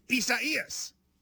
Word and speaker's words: Isaias: It looks like Isaiah, but without the H. – Spell it. Can you spell it for me? – I-S-A-I-A-S. – And it's four syllables. I do know that. I-S-A-I-A-S. And it's like. – I-S-A-I-A-S.